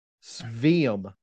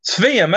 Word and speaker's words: Sveum: I'm gonna butcher this guy's last name, but third base, Dale Sveum. – Sveum, that's right. Dale Sveum. Yeah, I've heard of Dale Sveum. That's right.